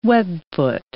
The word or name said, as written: webfoot